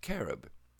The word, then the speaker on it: cherub: The word cherub comes from the Old Semitic carob, meaning to ride, and is correctly pronounced carob.